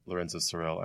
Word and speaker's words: Lorenzo Cyril: I'm Lorenzo Cyril.